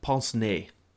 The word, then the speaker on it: pince-nez: Just so I don't look like an ass coming up in the next couple of minutes, how do you pronounce it? Is it pince-nez or pince-nez? – You're closer with the second one. It is pince-nez. – Pince-nez. Okay. – Yeah. You got to think pince-nez. French.